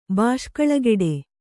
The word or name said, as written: ಬಾಷ್ಕಳಗೆಡೆ